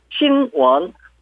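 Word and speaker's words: 신원 (新闻): 발음은 일성하고 이성입니다. 신원 뉴스, 신원.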